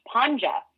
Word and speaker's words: Pandza: And when the DZ is together in the Croatian language, it's pronounced Pandza. So I grew up with all my friends calling me Pandza or Miss Pandza.